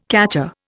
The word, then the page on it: catcher: Indian